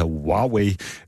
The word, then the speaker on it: Huawei: Jeg har lært at udtale det rigtigt, jeg har øvet mig i det, det hedder Huawei.